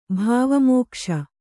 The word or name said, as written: ಭಾವಮೋಕ್ಷ